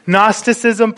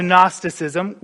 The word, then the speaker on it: Gnosticism: One of the first heresies in the early church, and you better believe it's a Christological heresy, is that of Gnosticism, which is spelt with a G, a silent G.